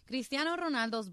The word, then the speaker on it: Cristiano Ronaldo: Cristiano Ronaldo's bodyguard reportedly crashed the soccer...